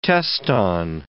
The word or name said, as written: teston